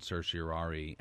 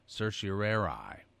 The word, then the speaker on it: certiorari: And last week when Justice Scalia and I recorded our audio book, we agreed on certiorari as the way we would say it throughout the book. But there is another pronunciation that my beloved mentor, Charles Allen Wright, always used, which is certiorari, which is also considered quite proper.